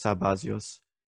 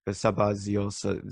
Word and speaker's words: Sabazios: I think I need to make a slight pronunciation correction. It's Sabazios. Sabazios. Zeus sound like Zeus a bit. Dios, a genitive form of Zeus, Lord of the Sabbath.